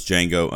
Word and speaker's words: Django: Number four was Django Unchained. The D is silent.